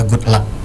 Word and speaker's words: GVDLVCK: Oke, jadi Good Luck-nya itu ya sahabat suara GVDLVCK ya. – Ejaan Indonesia Good Luck. – Oke, okay, Good Luck. Ejaan Indonesia ya, bukan English ya.